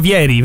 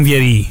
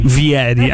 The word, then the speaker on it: Vieri: Primo, si pronuncia Vieri, vero? Non Vieri. – Vieri. – Anche. – Come Vieri, Bobo. – Come Bobo. Come Bobo. Sì.